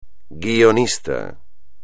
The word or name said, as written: guionista